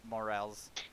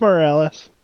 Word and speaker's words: Morales: So the PS5 launch title, Spider-Man Miles Morales. I'm sure I butchered that. I don't even give a shit. – You did. It's Morales.